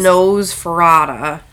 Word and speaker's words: Nosferatu: Nosferatu? – Okay, yeah. I thought you said it weird earlier and I was like, wait a second. But anyways. – Nosferatu.